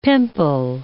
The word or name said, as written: pimple